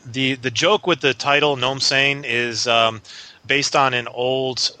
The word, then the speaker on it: Gnome Sane: Gnome Sane, and it's G-N-O-M-E, Gnome, Sane, S-A-N-E.